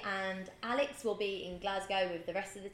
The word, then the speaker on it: Glasgow: I know, I always say it wrong. I say Glasgow. – It is Glasgow.